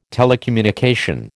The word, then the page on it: telecommunication: American English